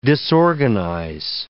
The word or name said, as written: disorganize